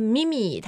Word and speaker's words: meme: 然后呢，它的拼法是 M-E-M-E, 复数后面加一个 S. 但是最重要的就是我们不能读成 meme, 它就是 meme.